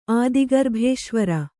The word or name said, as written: ಆದಿಗರ್ಭೇಶ್ವರ